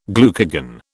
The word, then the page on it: glucagon: British English